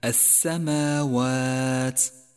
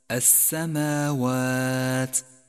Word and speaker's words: السماوات: لاحظ بعدها كلمة السماوات السماوات. أولا اللام شمسية تكتب ولا تلفظ، ولذلك لونت بالرمادي إشارة إلى عدم نطقها.